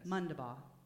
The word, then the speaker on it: Mondabough: Otherwise, I am Sadona Mondabough. – Ooh. Okay. Well, now remind me, so now I won't say it wrong. – Oh, okay. So Sadona? – Yes. – Mondabough.